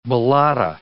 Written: balata

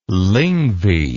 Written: lyngvi

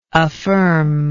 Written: affirmingly

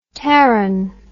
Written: terran

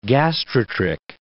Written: gastrotrich

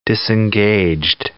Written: disengaged

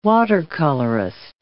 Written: watercolorist

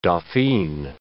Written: dauphine

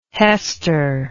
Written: Hester

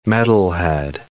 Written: metalhead

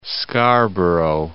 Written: Scarborough

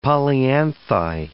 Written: polyanthi

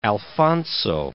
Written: Alfonso XIII

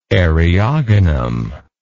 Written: eriogonum

